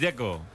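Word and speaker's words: Džeko: Porque de Z, ¿cómo se pronuncia? – Deco. Bruno Dzeko. Dice que es Deco.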